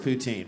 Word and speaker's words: Putin: It's actually pronounced Putin, but thank you.